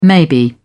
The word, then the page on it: maybe: British English